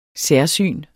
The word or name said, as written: særsyn